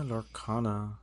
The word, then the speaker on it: Lorcana: I saw on the list that you, sir, have gotten into Disney's new card game, Lorcana, right? That's how you say it? – Yeah, Lorcana.